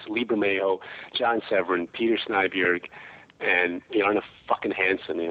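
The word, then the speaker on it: Snejbjerg: Is that how you say it? – Yes, that's how you say it, Snyberg.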